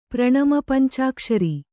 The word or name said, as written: ಪ್ರಣಮಪಂಚಾಕ್ಷರಿ